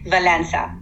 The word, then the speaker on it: Valença: Valença.